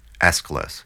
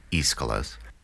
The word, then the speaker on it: Aeschylus: The Persians is the oldest play that survives from ancient Greece, written by the earliest of the big three tragedians, Aeschylus, sometimes pronounced Aeschylus, who definitely served in the Battle of Marathon in 490 BCE.